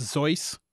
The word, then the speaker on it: Seuss: Seuss is actually pronounced Zeuss in German. But Seuss said that he liked that people thought of it as Seuss because it put him close to Mother Goose. So when you're saying Seuss, you're saying it correctly. But the German pronunciation is actually Zeuss.